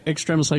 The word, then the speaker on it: Extremis: Wait. It's pronounced Extremis? – Extremis. – I've been fucking this shit up for like 3 years. – No, you're right. – I'm like Extremis, man. – No. Extremis like extremities.